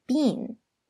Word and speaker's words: been: 要注意这个 been 的发音， B-E-E-N, 不是 been, 而是 been, 就中间那个两个 E 的发音，是吧，是那个小 E 的音。